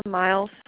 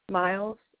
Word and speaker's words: Miles: Justina Miles. – Can you spell that for me, please? – Justina. – Justina. – J-E-S. J-E-S-T-I-N-A. – J-E-S-T-I-N-A. – Yes. – Your last name? – Miles, M-I-L-E-S.